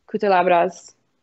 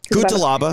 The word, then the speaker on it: Cutelaba: Uh, if you've heard any of Cutelaba's Kutalabras, did I say that right? Cutelaba.